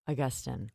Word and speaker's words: Augustine: Now, the name is spelled A-U-G-U-S-T-I-N-E. – Okay. – But Gabby makes a point of explaining on how to say the name, saying it's correctly pronounce Augustine. – Augustine. – Augustine. – Okay. – And this to me, like, it sounds like that's how you would say it if you had a Southern accent.